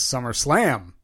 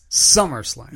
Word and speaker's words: SummerSlam: I keep hearing a promo running on the radio station for SummerSlam, and it annoys me because the big announcer voice puts the emphasis on the wrong word in SummerSlam. He says SummerSlam. – Oh, no. – It's SummerSlam.